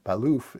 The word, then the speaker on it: Balluff: So a very interesting product from Baliff. I always say Baliff. Baliff is how they say it when we've had them on our show.